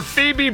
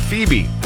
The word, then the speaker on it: Phoebe: We're sending you to Los Angeles for Phoebe Bridgers, the Lost Tour, with this new album that's coming out, and we'll get you there when you hear her voice. Just text the word Phoebe. P-H-O-E-B-E is how you spell it.